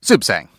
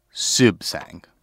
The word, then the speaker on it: Suebsaeng: Sibsang. – Sibsang. – It's very Thai. – Break it down into the syllables for me. – Aswin, last name, Sibsang.